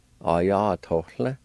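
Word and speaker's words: ayatolv: And right at the end of the word, there's a faint H sound between the O and the L. Pronounced like this. A-ya-to-la. A-ya-to-la. A-ya-to-la.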